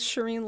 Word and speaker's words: Shereen: My website's Shireen Lani, L-A-N-I, S-H, well, my first name is S-H-E-R-E-E-N.